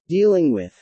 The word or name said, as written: dealing with